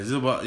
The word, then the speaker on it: Zubac: I butchered that man name every time I say it. See, I ain't even Zubac. – Oh, yeah, Zubac. Yeah, that's how you said.